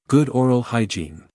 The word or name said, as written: good oral hygiene